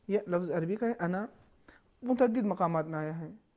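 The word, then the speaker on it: أنا: لیکن اگر ہم ملا کے پڑھیں انا انا فعبدون, یعنی میری عبادت کرو, تو الف نہیں پڑھا جائے گا. – الف نہیں پڑھا جائے گا, صرف نون اور نون پر زبر, زبر کا تلفظ ہوگا لیکن الف کا تلفظ نہیں ہوگا.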